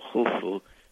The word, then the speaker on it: Khufu: and then there is a symbol which is a circle with crossed lines that supposedly represent a sieve that is pronounced. So it's U F U Khufu, which we call Cheops in English.